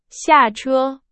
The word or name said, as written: 下车